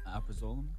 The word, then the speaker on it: alprazolam: Alprazolam. – Alprazolam? – Yeah.